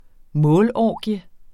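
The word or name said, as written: målorgie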